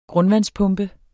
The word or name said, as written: grundvandspumpe